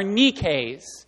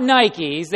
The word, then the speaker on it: Nike: So if Jesus were living today, the kind of shoes he would be wearing are Nikes. Not Nikes. They pronounce it wrong. It's Nike. That's the Greek word for victory.